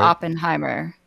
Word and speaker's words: Oppenheimer: Oppenheimer.